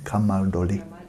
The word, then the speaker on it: Camaldoli: Did I pronounce it right? Camaldoli. Thank you. Camaldoli. It sounds much better when you say it.